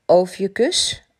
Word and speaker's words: Ophiuchus: Ophiuchus, zo spreek je dat uit.